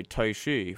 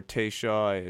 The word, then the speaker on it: Teishi: The song is called How Far by Taishi from, or Taishi, it's spelled T-E-I-S-H-I, two words separate.